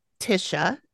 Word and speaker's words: Tysha: So anyway, the client is named Tisha. It is spelled T-Y-S-H-A though. – Okay. – Like Tisha, but it's Tisha.